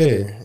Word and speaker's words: Ridder: Desmond Ridder? – Desmond Ridder, yeah. – Ridder is how they pronounce it. Ridder. It's Ridder, in my opinion.